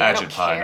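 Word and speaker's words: Ajit Pai: AgitPi. – No, there's no R in that. AgitPi.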